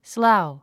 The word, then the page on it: slough: sláu; スラゥ